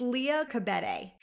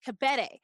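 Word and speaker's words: Liya Kebede: Leah Khadibi, is it? – It's Leah Khabede. – Khabede.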